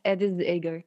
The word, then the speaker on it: Edith Eger: But anyways, and the other thing about Dr. Edgar, how do I say her name again? – Edith Eger. – Edith Eger. Yeah.